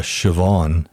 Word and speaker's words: Siobhan: Like the first time I saw Siobhan spelled out, I went, okay, there's no hope for this. No, right. Oh, listen. – Why? How did they spell that? – S-I-O-B-H-A-N. – Yeah. – Oh, like Siobhan kind of. – And Maeve. – M-A-E-V-E.